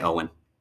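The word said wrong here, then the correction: Oisín: No. – No. – O'Sheen. – O'Sheen? – O'Sheen, all right. – Yeah. – And the final one we have is C-I-L-L-I-A-N.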